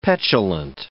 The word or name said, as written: petulant